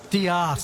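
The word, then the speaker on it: D'eath: It is called Diaeth Takes a Holiday. I was wrong. – Diaeth. – Diaeth. – Isn't that much nicer? – Yeah, it's spelled death with a apostrophe in it.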